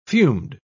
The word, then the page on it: fumed: British English